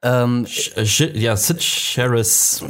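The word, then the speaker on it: Cyd Charisse: Sid Charisse.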